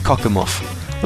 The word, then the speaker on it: Cockermouth: Never get tired of jokes about cockermouth, although up here it's pronounced cockermuff.